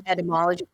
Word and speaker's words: etymology: And another thing we share, I love entomology, or what is it? Am I saying it wrong? – Etymology.